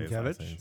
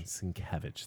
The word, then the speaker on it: Sienkiewicz: I never say Sienkiewicz. – Sienkiewicz. Thank you. I always pronounce that wrong.